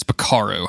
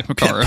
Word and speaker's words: Picaro: Meanwhile, Faye has decided to go after the leader of the gang, which I have written down as Picoro, but I don't think that is right. And I'm going to look up the correct pronunciation immediately. It is Picaro. I was absolutely right. So we're just saying Picaro.